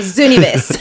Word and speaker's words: Zooniverse: It is pronounced Zoo as in the zoo, and Nivis without the U. So Zooniverse.